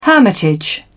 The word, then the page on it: hermitage: British English